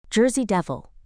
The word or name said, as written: Jersey Devil